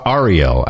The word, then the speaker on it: Ariel: Ariel, actually. Ariel. – Ariel. 5033. Please pronounce my name like this. R-E-L. With the stress on the last syllable. R-E-L.